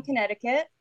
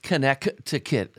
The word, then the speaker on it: Connecticut: And it's not pronounced Connecticut as of when I was a kid. There's an extra C in there.